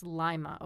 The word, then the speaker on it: Lima: She was born on this day in 1917 in Lima, Ohio. She died, actually it's pronounced Lima, Ohio, now that I have been there and there's not much there.